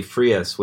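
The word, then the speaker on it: Freas: Since we had mentioned the Queen Owl, Frank Kelly Frias was the artist on that. – Editor's note, it's actually pronounced like freeze.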